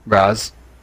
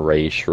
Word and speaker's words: Ra's: Raz. Yeah, or Raish. Raz or Raish. I've heard it said both ways.